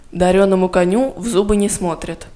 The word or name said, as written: дареному коню в зубы не смотрят